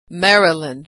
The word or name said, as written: Maryland